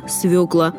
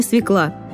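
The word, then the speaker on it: свёкла: Если бы ее всегда писали через «ё», ошибок в произношении бы не делали, ведь буква «ё» в слове всегда ударная. Поэтому правильно «свекла», но никак не «свекла».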